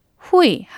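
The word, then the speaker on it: 후이 (会): 모든 거 하면 이치에, 모두 하면 또, 그리고 후이 하면 모으다, 모이다, 만나다라는 뜻인데 우리가 회의할 때 이 회자가 바로 후이라고 읽는 중국어의 글자입니다.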